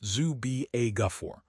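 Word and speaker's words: Zuby Ejiofor: The Atlanta Hawks are betting on multi-positional players like Zubi Ejiofor, pronounced Zubi Ejiofor, over big-name stars.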